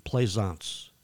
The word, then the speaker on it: Plaisance: You can find me on Facebook by looking up Michael and my last name, Plaisance, which is spelled P as in Paul, L-A-I, S as in Sam, A-N-C-E, Holyoke Bruise.